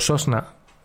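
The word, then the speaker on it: sosna (сосна): dado que en ruso la palabra pino, pronunciado sosna, es femenino.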